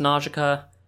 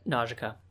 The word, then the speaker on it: Nausicaa: It's pronounced Nausicaa. The English translation got it wrong, and I have been rebuked for it. – Really? – Yep, Nausicaa.